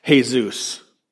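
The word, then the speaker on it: Jesús: But he introduced himself as Jesus. That's the Spanish way of pronouncing Jesus.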